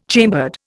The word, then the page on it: chambered: Indian